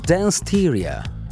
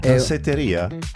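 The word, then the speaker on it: Danceteria: Dance-teria. – Danseteria? – No, è danseteria. – Sì. – Dance-teria si pronuncia. Io ho sempre sentito pronunciare così da quelli bravi.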